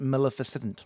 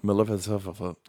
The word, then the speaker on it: Maleficent: it's pronounced Maleficent. – Maleficent.